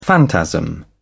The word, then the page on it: phantasm: British English